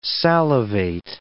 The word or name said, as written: salivate